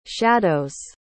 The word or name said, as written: shadows